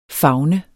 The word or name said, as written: favne